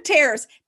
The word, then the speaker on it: Tares: Yeah, it's the Tares test and it's for ethical persuasion. – Wait, spell that, because it sounds like terrorist. – Tares. T-A-R-E-S. Perfect.